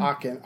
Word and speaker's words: Aachen: Am I pronouncing that right? Aachen? – I heard Aachen. Aachen. Hard C.